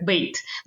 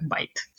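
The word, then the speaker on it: bait: Но это оказалось именно как бейт. Но из-за того, что она пишется B-A-I-T, а, а, мы уже в русском языке произносим как байт.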